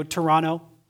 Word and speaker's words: Toronto: When I was 14, I was invited to go to Toronto, or if you're from Ontario, Toronto. The T is silent.